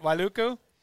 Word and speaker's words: Wailuku: You went to St. Anthony High School in Wailuku. Did I pronounce that right? Wailuku.